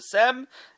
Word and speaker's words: Sejm: It was called the Sejm, S-E-J-M. J is in Polish, there's a lot of J's, silent J's in Polish words.